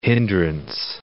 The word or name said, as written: hindrance